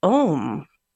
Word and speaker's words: Aum: And recently, I've been connecting with Aum. A-U-M.